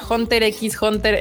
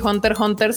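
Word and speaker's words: Hunter × Hunter: Se me perdió Ah, acá, Gilberto dice, ah, caray, yo decía Hunter X Hunter en vez de ignorar la X como tal. y se supone que Spy Family y Hunter Hunter se pronuncian sin las X como tal.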